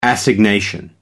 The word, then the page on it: assignation: American English